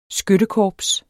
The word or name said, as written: skyttekorps